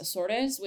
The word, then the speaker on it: Açores: So like I've been to the um, Azores, it's pronounced Açores, which is um, a municipality in the Atlantic that belongs to Portugal.